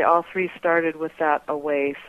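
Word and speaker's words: AWĀ: It's pronounced away, okay.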